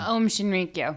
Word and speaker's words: Aum Shinrikyo: However, a cultist from the Japanese group, uh, which I cannot pronounce, but I will try Shinri, Shinriko, Shinriko. Uh, Aum Shinrikyo.